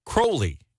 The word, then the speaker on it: Crowley: Yeah, believe it or not, Crowley pronounced his name with to rhyme with holy. So it's actually Crowley. Yeah, Mr. Crowley. Whereas Ozzy sings it to rhyme with Fowley.